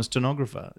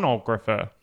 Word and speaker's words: stenographer: I've said that wrong for my. – What have you been saying? – Sonographer. – No, that's someone who does ultrasound, I think.